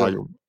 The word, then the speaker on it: Fayum: Und zwar, ich weiß noch nicht mal, wie ich es richtig ausspreche, Fayum von Friedmann Friese. Fayum, genau.